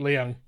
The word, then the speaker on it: Leung: And then Tony Leung. Leung? I never say his last name right. – Leung. – Yeah, Leung.